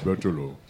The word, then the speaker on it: Berthelot: Pronounced Berthelot? – Bertolo.